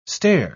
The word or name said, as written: stare